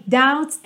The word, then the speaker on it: doubts: And all my doubts and fears kept me wondering, ok? And all my doubts and fears Doubts não se pronuncia a letra B, então você não fala doubt, e sim doubts.